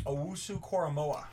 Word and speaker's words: Owusu-Koramoah: Owusu-Koramoah.